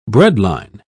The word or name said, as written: breadline